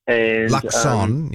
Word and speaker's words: Luxon: So Luxon and Luxon, yes. – Yeah. – We should try. No T in his name.